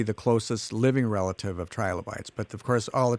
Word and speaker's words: trilobite: Trilobites, I always thought of it as trilobites, but it's trilobites is how it's pronounced? – Trilobites.